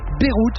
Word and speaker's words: Beirut: Au menu ce soir, un groupe qui sonne comme une destination, Beyrouth, ça se prononce comme la ville libanaise, mais ça s'écrit B-E-I-R-U-T, Beyrouth, le projet musical et voyageur mené par l'américain Zach Condon depuis 2006.